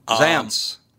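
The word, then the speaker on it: Zaentz: Zaintz. – Okay. – There's an E in it, but you don't pronounce it.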